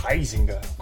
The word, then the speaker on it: Huizinga: Huizinga, ou Heisinger, como me disseram uma vez que é a pronúncia é certa, ele é um filósofo que escreveu um livro chamado Homo Ludens, onde ele explora o jogo e por que jogamos.